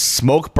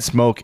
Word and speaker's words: Smoque: And for those that don't know, Smoke is spelled S-M-O-Q-U-E. So, yes, I have had Smoke.